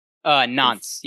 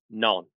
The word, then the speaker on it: Nantes: I don't think they pronounce the T in the T-E-S. So I think it's pronounced Non.